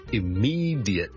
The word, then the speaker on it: immediate: 今天跟大家讲一个很常用的一个字的英文发音，就是呢 immediate, immediate, 立刻立即。咁有个听众问,diate 应该怎样读得好呢？正常呢，我们如果是很快地读呢，我们可能会读成 j, 好像 j,i,t 咁样读 j, 或者用一个很弱的刷音 immediate 加上个 ly 我就会听到 immediately 个 t 都可以不出现，